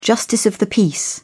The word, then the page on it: justice of the peace: British English